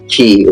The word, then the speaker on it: ki: Speaking of which, here in Hawaii, we have a plant called tea, and that's spelled T-I. Okay, in Hawaiian, it's actually ki, which is K-I.